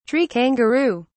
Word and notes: tree kangaroo: British English

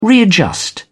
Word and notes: readjust: British English

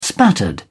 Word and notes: spattered: British English

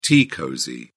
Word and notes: tea cosy: British English

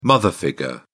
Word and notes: mother figure: British English

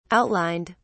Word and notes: outlined: British English

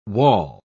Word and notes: waul: British English

